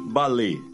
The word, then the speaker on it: balé: Você pronuncia balé ou balé? Tempos atrás, escutei um famoso e veterano cantor dizer repetidamente balé. Errado. A pronúncia correta em português é aberta, balé. Acho que nosso cantor pensou que, por se tratar de palavra francesa, em que o E, com acento agudo, é pronunciado fechado, devesse também dizer balé. Equivocou-se. O certo é mesmo balé.